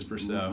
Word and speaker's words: Brousseau: Aroldis Chapman throws at Rays Mike Brousseau. Did I say that right? – Yes, that's Brousseau.